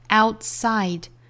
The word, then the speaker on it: outside: 那这样的发音过程其实就是不完全爆破，很多时候会出现在单词内部，比如说 outside, out 里面的 t 的发音就是做不完全爆破， outside, outside.